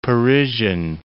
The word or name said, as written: Parisian